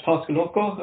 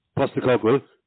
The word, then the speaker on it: Postecoglou: It's Ange Postecoglou, am I right? – No. Oh, God, don't say that out loud again. Okay, two things. Postecoglou.